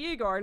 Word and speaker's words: Igor: Yeah, you must be Igor.